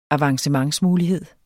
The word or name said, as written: avancementsmulighed